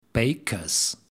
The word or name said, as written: Baker's